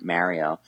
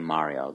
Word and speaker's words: Mario: Now that I feel there's more pressure now to say Mario, like the authentic Italian accent. – I think that's the correct pronunciation. I have actually married into an Italian family, and my pasta has now become pasta. – So I guess the Mario thing, it's the long A, right?